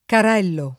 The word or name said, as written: Carello